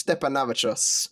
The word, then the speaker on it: Steponavičius: We'll call him Mr. Irish Stepanavichos. – Oh, welcome. – Fantastic pronunciation.